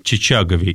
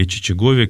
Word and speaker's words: Чичагов: Мы говорим и о священнослужителях, мы говорим о таком известном священнослужителе, митрополите Серафиме Чичагове или Чичагове, как по-разному произносится его фамилия,